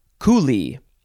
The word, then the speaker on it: coulee: Next word is coolie. You have to emphasize the first syllable, C-O-U-L-E-E, coolie, coolie, coolie.